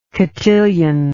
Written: cotillion

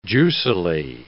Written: juicily

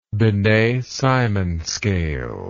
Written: binet simon scale

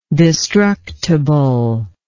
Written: destructibleness

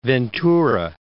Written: Ventura

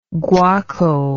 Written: guaco

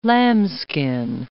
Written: lambskin

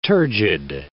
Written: turgid

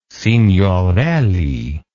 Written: signorelli

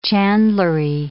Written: chandlery

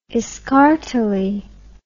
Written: escartelly

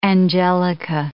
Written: angelica

